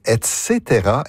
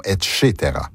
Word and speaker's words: et cetera: Comment doit-on prononcer l'abréviation latine ETC point? On prononce et cetera et non et cetera.